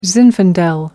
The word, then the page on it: zinfandel: American English